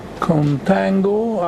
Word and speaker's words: contango: This situation is known as contango. I don't know where this word comes from, but it nicely rhymes with tango, and I use this obviously tongue-in-cheek.